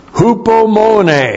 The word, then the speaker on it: hupomone: hupomone. The first magnificent virtue of Christ developed in your heart. Hupomone. H-U-P-O-M-O-N. Long E. Hupomone.